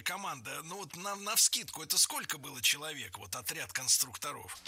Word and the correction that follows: конструкторов: Конструкторов, конструктора, это неверно. В слове «конструктор», опять же, ударение не меняется. Всегда сохраняется на «у» во втором слоге. Конструкторы, конструкторов, конструктором.